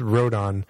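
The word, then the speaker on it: Rodón: It's Rodon. There's only one N.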